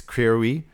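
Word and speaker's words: Creirwy: She had a daughter who was really beautiful and going to really massacre the Gaelic pronunciation of her name. It's Creary, and she was gorgeous, beautiful.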